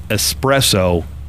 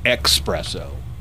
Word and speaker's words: espresso: And 45% of coffee snobs, so these are people that say they are coffee snobs, judge you for pronouncing espresso, expresso. So if they hear you say expresso, just know that you're being silently judged.